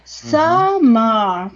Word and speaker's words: summer: Summer. Porque las R al final no suenan casi. – Summer. En el inglés británico no suenan.